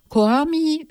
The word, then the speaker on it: COAMI: Koami wird genauso geschrieben, findet ihr auf dem Internet, wie es ausgesprochen wird.